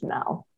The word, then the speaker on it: Mel: And it sounds like Mal. – Yeah. – So the joke is, my name is not Mal, it's Mel.